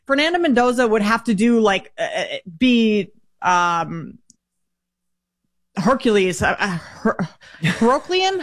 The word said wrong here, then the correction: Herculean: How do you say that word? – Herculean. It would take a Herculean season.